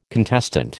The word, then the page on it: contestant: American English